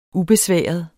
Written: ubesværet

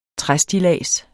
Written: træstillads

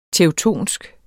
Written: teutonsk

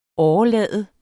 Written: årelade

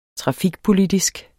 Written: trafikpolitisk

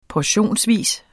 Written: portionsvis

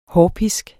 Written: hårpisk

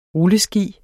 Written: rulleski

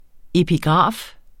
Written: epigraf

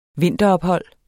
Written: vinterophold

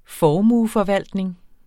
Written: formueforvaltning

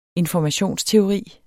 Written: informationsteori

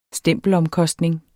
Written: stempelomkostning

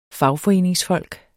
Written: fagforeningsfolk

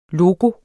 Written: LOGOERNE

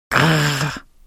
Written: grr

